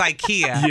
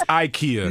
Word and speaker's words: IKEA: So she tells me I mispronounce IKEA for IKEA. – You say IKEA? – Which one do you say? – Oh, you say IKEA. – Uh-huh. – Ah, it's IKEA.